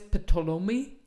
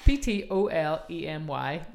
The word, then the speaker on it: Ptolemy: It begins with PT. Claudius Ptolemy. Can you see it there? – PT. I can see it. I can't say it. – Yeah. Ptolemy.